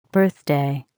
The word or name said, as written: birthday